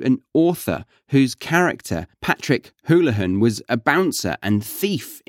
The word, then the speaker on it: Houlihan: Again, that who sound, Houlihan.